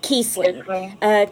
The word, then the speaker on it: Kiesling: Kiesling. K-I-E-S-L-I-N-G.